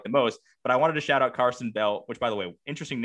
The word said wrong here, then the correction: Karsten: K-A-R-S-T-E-N. Carsten.